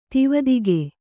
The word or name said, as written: ತಿವದಿಗೆ